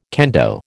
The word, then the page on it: kendo: American English